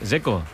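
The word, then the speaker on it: Džeko: Porque DZ, ¿cómo se pronuncia? – Dzeko. Bruno Dzeko. Dice que es Dzeko. – Como tirando a J, Dzeko o algo así.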